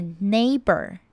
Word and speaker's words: neighbor: 还要注意到的是 GH 是不发音的。再来呢我们要注意到字尾 OR。一般来说如果 OR 摆在字首像 order, 那 OR 就会念 OR 的音。但是在这里它是放在字尾。所以呢会像我们一般看到的 ER 结尾的字都会是念成 ER 的音。所以是 neighbor。